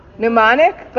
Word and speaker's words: mnemonic: Minonic? What? Mnemonic? But it actually starts with an M. The M is silent.